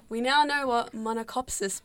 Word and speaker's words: monachopsis: So, remember there is, it is spelled M-O-N-A-C-H-O-P-S-I-S. There's no R in that one. – Monocopsis.